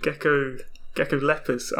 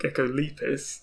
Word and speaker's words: Geckolepis: It's actually interesting, you pronounce it Geckolepis. I'd always pronounced it Geckolepis.